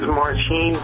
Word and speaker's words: Martin: It really is Martine, but it's spelled the same way, and yes.